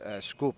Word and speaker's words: Skopje: When I say that the Albanians were saying skup when they were talking about the bus going to Skopje, that's how they pronounce it. And I was just wondering, we're pronouncing it like the Macedonians pronounce it.